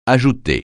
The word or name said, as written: ajouter